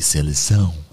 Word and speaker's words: Seleção: Die Seleção. Ja, du sprichst das auch immer so wie so ein Native Speaker aus.